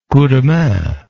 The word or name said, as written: coup de main